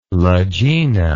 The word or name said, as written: lagena